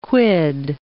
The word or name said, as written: quid